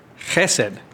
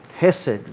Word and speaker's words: chesed: Now, I did a word study on loving kindness, and in the Hebrew, do you remember how to pronounce it? – Chesed. – Chesed, right.